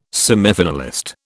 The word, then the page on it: semifinalist: British English